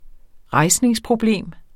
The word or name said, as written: rejsningsproblem